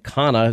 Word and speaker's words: Khanna: The bill sponsored by Representative Roe, I think Khanna is how you pronounce it, comes as President Trump prepares to announce his nominee Saturday.